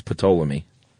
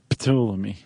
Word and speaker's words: Ptolemy: So, Ptolemy, which has got a silent P, by the way. – Yeah, it's a cool name. – Which is why it's such a great name. It's Ptolemy. – Ptolemy.